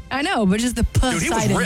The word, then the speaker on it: Poseidon: Poseidon is correct. – A not silent P that I feel like should be silent.